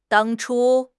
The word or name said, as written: 当初